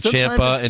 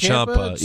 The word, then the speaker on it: Ciampa: Well, they fucking mispronounce Ciampa's name all the time. – Yeah, they say Ciampa and Ciampa. Yeah, sometimes it's Ciampa.